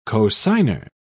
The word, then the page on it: cosigner: British English